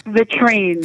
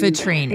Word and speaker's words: vitrine: Vitrine. – Vitrine. – Yeah. – Okay. – Oh, it sounds, it rhymes with latrine.